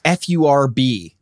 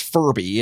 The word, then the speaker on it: FURB: And, in the US and in the UK, it was FURB by right. – Frankie. And that's not pronounced Furby. It's pronounced Furb, and it stands for fuck you right back.